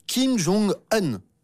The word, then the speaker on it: Kim Jong-un: Je veux bien sûr parler de Kim Jong-un, ou plutôt, comme on dit en Corée du Nord, Kim Jong-un. Oui. Il faut dire comme ça, comme un gun, le revolver. C'est un bon moyen mnémotechnique. C'est ainsi qu'on prononce en Corée du Nord.